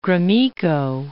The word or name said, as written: Gromyko